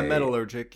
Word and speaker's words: metallurgic: I don't know how to pronounce that word. It's M-E-T-A. – Yeah, metallurgic. You got it.